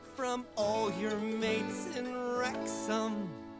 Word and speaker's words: Wrexham: with Wrexham, and that's got a silent W.